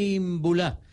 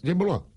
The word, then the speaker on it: Imbulá: Gilbert Janel Imbulá. – Así no se dice. – ¿Cómo es? – Imbulá. – Imbulá.